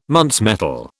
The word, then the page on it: Muntz metal: British English